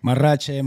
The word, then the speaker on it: Marrache: Marrache, mariachi. – Marrache. – Mariachi. All right. Everybody says mariachi. – Marlon Marrache. All right. I had to roll that R.